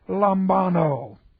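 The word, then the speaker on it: lambano (λαμβάνω): Aorist active infinitive of the verb lambano. L-A-M-B-A-N-O, long O.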